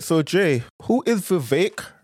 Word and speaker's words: Vivek: I'm glad you said Vivek rhymes with cake because the mispronunciations of his name are so... Anyway, I'm glad you said Vivek like cake because his name is frequently mispronounced.